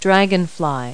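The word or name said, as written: dragonfly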